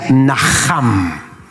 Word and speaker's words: naham: que se pronuncia naham. Y quiero que lo digas conmigo, pero hacele fuerza a la J, todavía no.